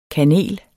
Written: kanel

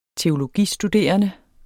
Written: teologistuderende

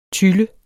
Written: tylle

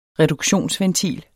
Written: reduktionsventil

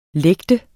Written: lægte

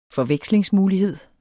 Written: forvekslingsmulighed

